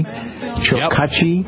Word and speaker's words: Chokachi: And that person today is David Chokachi. Am I pronouncing that correctly? Chokachi, yep.